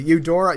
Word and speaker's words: Eudora: She was Yoroda, is it? Yoroda? I don't know how to pronounce it, actually. Eudora, you know, sorry, Eudora, Tiana's mother in Princess and the Frog.